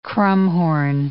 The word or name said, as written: crumhorn